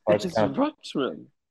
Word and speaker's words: Rutschman: I thought it was Rutschman. I don't know. I don't know how to pronounce it. – It's Rutschman.